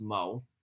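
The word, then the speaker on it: Mu: Check out that article, A Thing Mo, even though her name is spelled M-U, it's pronounced Mo.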